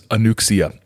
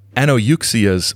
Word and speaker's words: Anouxia: So as we begin the chapter 7, where John is examined, he meets a fellow whose name is spelled A-N-O-U-X-I-A. Now, on a Facebook Live thing that I did, I pronounced this Anuxia, even though deep down, because I'd read the book, I knew this wasn't correct. But listener and longtime saucer scholar Martin Kottmeyer sent me an email correcting my pronunciation of it as Anuxia, saying, quite rightly, that Anouxia's proper pronunciation is Anouxia, as listed on page 79.